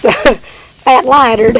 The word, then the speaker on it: lightered: Call it, I'll tell you the real old country pronunciation. It's fat lightered.